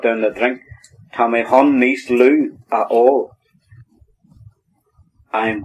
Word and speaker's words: chun: Now that word, Charlotte, C-H-U-N, but it's pronounced hun. You don't hear the C.